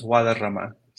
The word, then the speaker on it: Guadarrama: And my last name is Guadarrama.